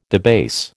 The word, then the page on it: debase: American English